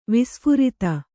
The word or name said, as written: ವಿಸ್ಫಾರಿತ